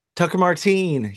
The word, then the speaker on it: Martine: And how do you pronounce his last name? Is Martin or Martine? – Tucker Martine, yep, in Portland, Oregon.